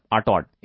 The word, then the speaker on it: Artaud: ARTAUD, आर्टॉड, in the 1930s।